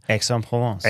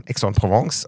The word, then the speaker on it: Aix-en-Provence: Aix-en-Provence. – Aix-en-Provence. Nicht Edge, sondern Aix-en-Provence.